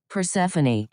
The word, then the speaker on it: Persephone: Persephone.